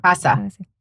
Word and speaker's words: Aissa: I think it's, instead of Alyssa, I think it's called Asa. A-I-S-S-A. – Huh. Asa. – Asa. – Asa. – A-I-S-S-A.